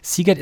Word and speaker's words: Sziget: Was ist das Festival mit diesem schwer aussprechbaren Namen Sziget? – Ja, man spricht sogar Sziget tatsächlich Sziget aus, habe okay.